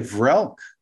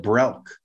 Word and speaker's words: Vrelk: It's Vrelk with a B. Vrelk is what I wrote. – Well, then I went to the Voyager script for this episode, and it's spelled V in that one. So really? Yeah. Okay, Vrelk. All right. – V-R-E-L-K. – I thought he said Brelk.